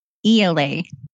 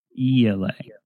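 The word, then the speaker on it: Ialai: Mike would like me to provide the actual pronunciation, which is ELA. – ELA.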